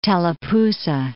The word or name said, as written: Tallapoosa